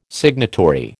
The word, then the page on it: signatory: American English